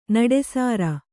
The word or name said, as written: ನಿಟ್ಟುಸಿರು